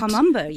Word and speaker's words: Kom Ombo: Uh, the Kom Ombo, am I pronouncing it? Kom Ombo, yes.